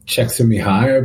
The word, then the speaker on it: Csikszentmihalyi: Csikszentmihalyi, I believe is how he says his name. – Mihai Csikszentmihalyi, yeah. You said it's so different than it looks.